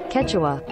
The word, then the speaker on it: Quechua: Quechua.